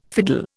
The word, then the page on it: fiddle: Indian